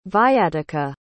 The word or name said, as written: viatica